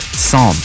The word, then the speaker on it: Psalm: So now they've got Psalm. Silent P.